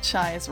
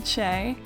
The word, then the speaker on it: Chay: Now, you may have noticed that some of us have pronounced her name Che, and that's how it's spelled in English, C-H-A-Y, but when I looked it up today, it was actually written Chai in the Japanese phonetic script.